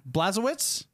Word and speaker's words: Blazkowicz: Blazkowicz. – Yeah. Blazkowicz. – Yeah. That's. – I don't even know. – How I say it. – That's how you say it.